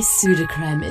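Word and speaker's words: Sudocrem: There are many reasons why pseudocreme is the nation's favourite nappy rash cream. Pseudocreme helps soothe, heal, and protect your baby's delicate skin. Pseudocreme, mum's number one choice.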